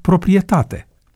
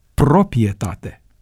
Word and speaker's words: proprietate: La fel este termenul proprietate, pe care unii îl pronunță proprietate.